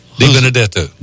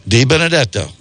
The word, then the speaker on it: Di Benedetto: I'm just happy that I finally learned how to say his last name. Di Benedetto. – Di Benedetto. That's right.